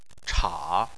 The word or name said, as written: cha3